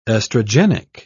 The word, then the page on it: estrogenic: British English